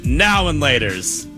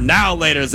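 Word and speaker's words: Now and Later: So I was just doing the two different pronunciations. I say now and laters. You say now laters like it's all one word.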